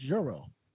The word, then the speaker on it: gyro: Until I went to Greece, I didn't know that gyro was officially pronounced euro.